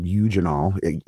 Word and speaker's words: eugenol: They're loaded with a compound called eugenol, E-U-G-E-N-O-L. I think I'm pronouncing that right.